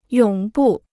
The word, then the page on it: 永不: yǒng bù